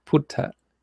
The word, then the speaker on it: Putta: And that the Buddha had said that he has now said that he wants to be referred to as Putta, because that's the Thai pronunciation of the word Buddha.